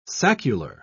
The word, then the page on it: saccular: British English